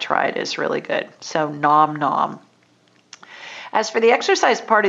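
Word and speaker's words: Gnom Gnom: I find delicious low-carb recipes at Nom Nom, and it's spelled GNOM-GNOM. And they have great recipes on there.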